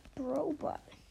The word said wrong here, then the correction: Brawl Ball: äh, Brawl Ball, wenn ich das jetzt richtig ausspreche.